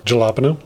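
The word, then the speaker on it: jalapeño: For those listeners that don't know, the J in jalapeno is spelled... – Jalapeno? – It's like a Y or an H.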